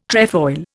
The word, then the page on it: trefoil: Indian